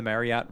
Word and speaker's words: Marriott: So that Marriott, by the way, I learned from like the boss of the Marriott resort chain that it's actually pronounced Marriott, like chariot, and we've all been pronouncing it wrong.